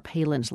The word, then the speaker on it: Palin: Now, the name is spelled just as Governor Sarah Palin's last name is, P-A-L-I-N, but it's from Chile, so Palin is pronounced Palin.